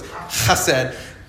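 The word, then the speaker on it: chesed (חסד): It's the Hebrew word chesed. And let's all say that, and we got to say it like nice Jewish people, even though we're probably not most of us Jewish in here, so it's chesed.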